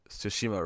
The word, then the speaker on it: Tsushima: That's, a, that's, that's, why, that's why I bring that up, is because everyone's complaining that most people don't say. Uh, Tsushima right because they just ignore the T and just say it with an S.